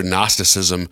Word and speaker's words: Gnosticism: Gnosticism, Pelagianism, and by the way, if you're listening to this and then you go and read it and you're looking for Gnosticism, you'd be like, well, they talk about this, but it's spelled with a G-N.